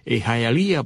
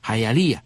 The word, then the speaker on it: Hialeah: Y uno comenzó a escuchar hablar de Hialeah, por supuesto que Hialeah, pronunciado en inglés, suena un poco distinto, pero uno lo escuchaba así a la cubana, Hialeah.